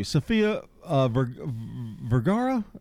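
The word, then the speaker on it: Sofía Vergara: 1972, Sofia Vergara? Is that how you say it? – Mm-hmm. – Okay.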